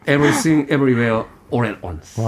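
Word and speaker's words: Everything Everywhere All at Once: 네 개나 별점을 줬는데 발음에 네. 좀 신경 써 주시죠. – 아, 네. 그러니까 저는 이게 안 되더라고요. 고등교육을 못 받아서. Everything, everywhere, All at Once.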